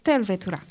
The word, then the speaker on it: Tervetuloa: It's not Tervetula. It's Tervetula. In Finnish, the first syllable in the word is always stressed.